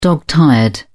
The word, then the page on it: dog tired: British English